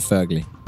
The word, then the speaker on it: Wögerli: Personen som har bokat heter Werner Wögerli. – Kock. – Ja, men det är en klassisk kock. – Fast man uttalar det Fögerli.